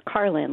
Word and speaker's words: Carolyn: It's Carlin, like George Carlin.